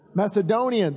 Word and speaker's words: Macedonian: Now it's called the Macedonia, I'm sorry. Pronounce that for me, somebody. Macedonian. There he goes. Once I heard it, I knew I could say it after that.